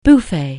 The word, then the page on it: buffer: anglais